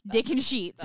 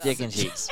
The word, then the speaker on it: Dickensheets: D-I-C-K-E-N-S-H-E-E-T-S. Dick and Sheets. – Dick and Sheets.